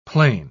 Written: plane

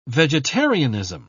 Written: vegetarianism

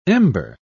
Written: ember

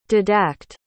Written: didact

